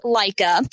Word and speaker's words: Leica: It's pronounced Leica, and Leica cameras have been around for a while.